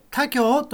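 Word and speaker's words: 田京: やがてどっかで見たことがある駅名が、田んぼに京都を書いてね、他京と読むんですが、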